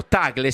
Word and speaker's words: Tagle: I nomi che danno sono il filippino Tagle, si pronuncia? – Giusto.